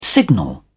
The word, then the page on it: signal: British English